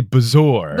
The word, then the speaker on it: bezoar: I've never known how to pronounce Bezor. B-E-Z-O-A-R. – Yeah, we talked about this last time. – Yeah, they say Bezor.